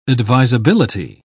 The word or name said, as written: advisability